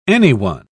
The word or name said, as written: anyone